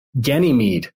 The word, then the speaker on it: Ganymede: Ganymede, from like Ganges River and mead, the drink, the mead.